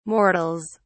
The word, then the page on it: mortals: British English